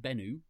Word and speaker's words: Bennu: Asteroid Bennu.